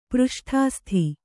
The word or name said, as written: ಪೃಷ್ಠಾಸ್ಥಿ